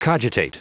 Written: cogitate

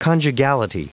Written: conjugality

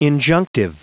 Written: injunctive

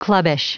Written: clubbish

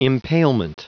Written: impalement

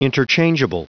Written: interchangeable

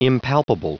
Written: impalpable

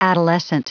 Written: adolescent

